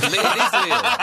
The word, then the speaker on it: Lille: Help me with my French pronunciation here. – Oh, Jesus. – It's Lille. – It is Lille.